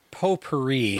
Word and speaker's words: potpourri: Now, probably the best analogy, more so than a rose, is how do you pronounce it? Potpourri? Potpourri? – Potpourri. Potpourri. For, for those elitist uh, intelligentsia among us, yes. It's right. Potpourri, not pot, potpourri.